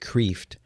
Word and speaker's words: Kreeft: It's called Fundamentals of the Faith, and that's by Dr. Peter Kreeft, K-R-E-E-F-T. I know it looks like Kreeft, but he pronounces it Kreeft.